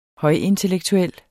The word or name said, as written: højintellektuel